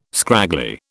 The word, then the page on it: scraggly: British English